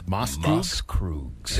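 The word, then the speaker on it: Maßkrug: So this beer, those are known as Moskrugs, kids. – Moskrugs.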